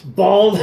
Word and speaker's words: Bawls: So what we got is, I don't know how you pronounce it, but it's spelled B-A-W-L-S. – Balls. – Balls. Guarna? – Guarana? Guarana? – G-U-A-R-A-N-A.